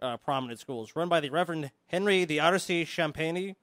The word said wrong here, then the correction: Champney: I think it's Champney. – Champney, yeah, Champney, whom Crowley considered a sadist.